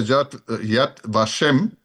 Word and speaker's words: Yad Vashem: And you took me to the Yad Vashem. I always have the pronouncing, the Holocaust Memorial. Yeah, that's right. You have it.